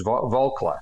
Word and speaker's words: Volcker: That was the somebody told me I was pronouncing his name wrong. It's Volcker. Mm. Is that correct? – Yeah.